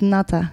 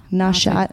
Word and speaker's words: Natha: Yeah, it's pronounced Natha. – Yeah, Natha. – Nasha. I thought it was Nasha. – It's Natha. Natha Yoga Center.